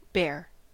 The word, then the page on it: karhu: [ˈkɑrxu]; /ˈkɑr.hu/